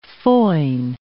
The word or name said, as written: foin